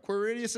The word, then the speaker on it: Quirinius: This was the first census that took place while Quirinius. Is that how you say that? Somebody talk to me. Okay, all right. Quirinius it is.